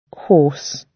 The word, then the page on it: horse: British English